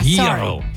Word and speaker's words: gyro: Hero. – With a Y. – Yeah, gyro. – Sorry, yes, yeah.